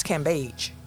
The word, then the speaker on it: Cambage: Cambage. We've been pronouncing her name wrong. It's Cambage.